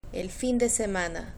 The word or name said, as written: el fin de semana